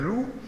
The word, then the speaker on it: loup: Mais du coup, alors on se dit, bon, il exagère, alors il explique, je vous le lis, hein, le P du loup ne se lit jamais, un loup enragé.